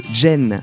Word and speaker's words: Zen: Il s'agit de Jen, ça s'écrit Z-E-N.